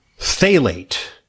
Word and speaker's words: phthalate: phthalate. That's got a weird spelling. P-H-T-H, pronounced phthalate. The P-H is silent, I guess.